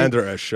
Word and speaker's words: Vander Esch: Vander Esch, yeah.